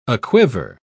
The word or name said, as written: aquiver